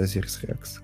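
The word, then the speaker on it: OSIRIS-REx: А новость о том, что НАСА взяла образец а, астероида Азирис Рекс. Да, вот так читается Азирис Рекс.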